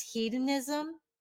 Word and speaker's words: hedonism: And you, and the next one I have is hedonism. Is that how you say it? – Yeah.